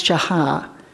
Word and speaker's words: shachah: The Hebrew word that Abraham uses here when he says we're going to go worship and come back, that word is, it's spelled S-H-A-C-H-A-H, and it's pronounced Shaha.